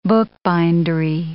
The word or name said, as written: bookbindery